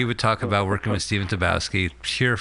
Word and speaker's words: Stephen Tobolowsky: Well, you know, he also, Stephen Tobolowsky. – That's how you pronounce it.